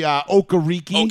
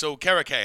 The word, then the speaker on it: Okereke: They were like, yeah, we've been saying it wrong. It's Okereke. And I'm like, no, it's not. It's Okereke.